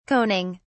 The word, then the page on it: coning: British English